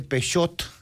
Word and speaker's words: Peugeot: Peugeot. No es Peugeot. Pero aquí le dicen Peugeot, sí, porque hay una T. Y te voy a ser sincero, cuando después tienes que explicar por qué no se pronuncia la T, ni yo sé.